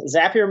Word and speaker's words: Zapier: Now, first off, to rest all of the pronunciation to rest here at this point, it is Zapier, correct? – Yes, Zapier makes you happier.